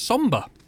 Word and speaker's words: Sombr: That's Somber. Somber. It's spelled S-O-M-B-R.